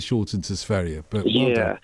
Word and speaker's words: Sverige: No, Sverige. Sverige is the right, correct pronunciation. Well done, you, Ruby. Yeah, it's Sverige.